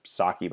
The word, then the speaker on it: sake: Sake. – Sake, okay. – Sake. – So it's pronounced in the same way as poke? – Yes.